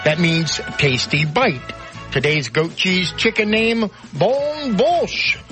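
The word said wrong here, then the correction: bonne bouche: B-O-N, you know French, B-O-N-N-E, Bouche. B-O-U-C-H-E. Bon yeah, Bouche. – Bon Bouche.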